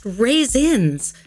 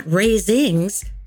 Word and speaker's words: raisins: "It's pronounced raisins, not raisings," Patty corrected.